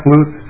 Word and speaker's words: Lucht: My name is Chuck Luce. It's spelled L-U-C-H-T, not pronounced that way, but I am chairman of Friends of Jackson County Trails Association.